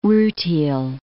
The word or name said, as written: rutile